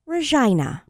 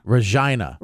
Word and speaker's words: Regina: It's pronounced Regina. – Regina. – Regina. – Hmm. – And this is their... It sounds awfully close to something else.